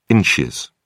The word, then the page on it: ins: British English